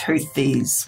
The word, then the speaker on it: Houthis: Am I saying it right? Is it Houthis? – That's correct. Some people pronounce it Houthis, but it's Houthis with a hard H at the beginning.